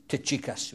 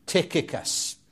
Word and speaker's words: Tychicus: In Wales, Tychicus, we call him. Tychicus. But hard C, C and a H, Tychicus is the usually accepted way of pronouncing his name.